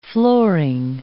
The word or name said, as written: flooring